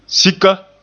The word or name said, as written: sika